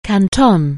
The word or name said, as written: Canton